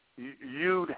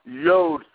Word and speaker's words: Yod: Yud Hey Vav Hey, or Yod, some scholars pronounce it Yod Hey Vav Hey.